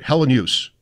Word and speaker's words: Helenius: Helen Hughes. – Right. – Kind of like no particular emphasis on any syllable, and I would say that it's three syllables.